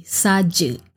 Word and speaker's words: saja: Tengok, aje, A-J-E, aje. Well, that is the street language, but the proper Malay word would be saja, S-A-J-A.